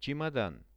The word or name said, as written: chemodan